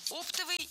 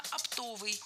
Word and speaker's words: оптовый: Оптовый – неверно. Правильно, оптовый. Ударение в этом слове падает на второй слог, на суффикс «ов» – оптовый.